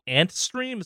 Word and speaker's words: Antstream: They've invested heavily in a company called Antstream. Is that how I pronounce that one, Henry? – Yes.